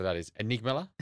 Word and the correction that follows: amygdala: isn't it? It's called amygdala.